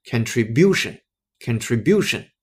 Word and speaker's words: contribution: 包括它的名词形式后面呢，把 e 换成 t i o n 的结尾，那个时候的重音再往后移一个音阶，重音在那个 bu 那个音阶上。于是我们把它的名词读成 contribution，contribution。 OK，这个声音才正确。